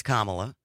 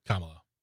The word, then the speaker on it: Kamala: It's pronounced Kamala. – Yeah. – I do think it's been long enough now that we can all learn how to pronounce her name. – Yeah. – It's Kamala.